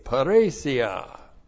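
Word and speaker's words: parresia: Looks like that in the Greek, P-A-R-R, long E-S-I-A, paresia.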